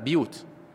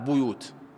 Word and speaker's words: بيوت: بعض القراء مثل شعبة يقرؤونها بكسر الباء في بيوت أذن الله أن ترفع في كل القرآن فينطقونها بكسر الباء بيوت، وغيرهم من العرب ينطقونها بضم الباء بيوت،